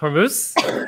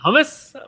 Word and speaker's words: Hormuz: I'm pretty sure it's pronounced Hormuz, not hummus, but thanks for that one.